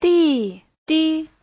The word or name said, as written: di4di5